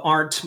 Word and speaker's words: Arndt: So if you look up Rob Arndt, my last name is spelled A R N D T.